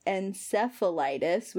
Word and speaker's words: encephalitis: or I'm going to pronounce this wrong, encephalitis, which is an inflammation of brain tissue. – I think you actually pronounced that correctly.